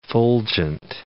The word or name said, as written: fulgent